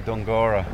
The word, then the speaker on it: Dunguaire: It's spelled Dungura, D-U-N-G-U-A-I-R-E, but all the locals say Dungura.